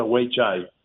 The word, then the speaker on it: Weichai: se escribe rarísimo pero se pronuncia Weichai